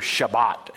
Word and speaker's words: Shabbat: It's pronounced in the Hebrew Shabbat, and it actually means to cease or desist.